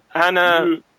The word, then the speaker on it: Anne: Anna is a man. – Yeah, of course, Anne is a man sounds better, but Anna is the way that you actually should pronounce my name.